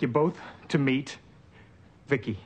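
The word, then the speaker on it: V.I.C.I.: V-I-C-I, I pronounce it Vicki.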